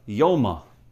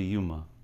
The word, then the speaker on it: Yoma: It used to be Yuma. Until Art Scroll came out. Daf Lamed Zayin Amad Aleph. And just Agav, the correct pronunciation is Yoma or Yoma if you want to be Yeshivish.